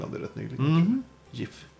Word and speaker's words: GIF: Jag har sakta men säkert börjat säga GIF också. För det är så man ska uttala det.